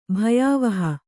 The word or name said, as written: ಭಯಾವಹ